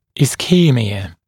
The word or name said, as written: ischemia